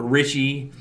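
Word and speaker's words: Reiche: The dudes behind Toys for Bob, and I hope I don't butcher this guy's name, Paul Reich. It's R-E-I-C-H-E. Or Richie the Third, and Fred Ford.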